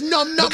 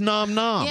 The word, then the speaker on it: nom nom: Who cares if it's num num or nom nom? – Because it's nom nom. Yeah, it but is, you know what I meant. – And it's spelled N-O-M.